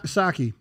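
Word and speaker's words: Psaki: Saki. – Like Saki Bomb. – Yeah, the P is silent.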